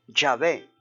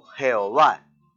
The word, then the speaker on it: Yahvé: Y fue precisamente ese nombre en que dio origen a esa frase o a ese tetragrama, que son cuatro letras, sagrado Yahvé o YHVH, ese tetragrama, y que al sumarle las vocales da lugar a un nombre transliterado que se pronuncia Yahvé o Jehová.